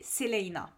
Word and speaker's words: Celaena: En fait, le, le nom du, du personnage principal, c'est Selena. C'est un nom qui est assez particulier parce que ça s'écrit C-E-L-A-E-N-A.